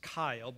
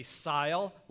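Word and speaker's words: Cyle: My middle name is Kyle, but it's spelt with a C. It's C-Y-L-E. So throughout my life, I've had people say Sile, Lyle. I've had people say Cycle.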